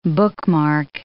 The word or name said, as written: bookmark